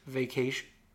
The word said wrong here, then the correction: vacuous: V-A-C-U-O-U-S... experiences I've ever had. – Vacuous.